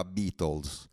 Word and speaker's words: Beatles: E a un certo punto mi trovo davanti un 45 giri dei Beatles, di cui non solo sapevo leggere il nome perché mi avevano spiegato che quello si leggeva Beatles, ma riuscivo anche a leggere il titolo.